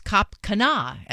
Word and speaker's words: Cap Cana: The Jim Kerr Rock and Roll Morning Show is in the Dominican Republic for a working vacation of Secrets Cap Cana, as Jim told me it's pronounced.